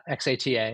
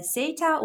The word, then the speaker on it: Xata: Uh, so it's like data, but uh, uh, there are two pronunciations, Zeta or Xata, depending on where you live in the world.